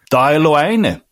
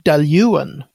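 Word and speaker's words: Dalwhinnie: Det uttalas så här. Daljuan.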